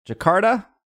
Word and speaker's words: Jakarta: I'm going to say this wrong. Jakarta? – You got it.